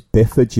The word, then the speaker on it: Byford: I think it's Bifford, you know. Although it is spelt Byford. But yeah, I think it's Biff Bifford.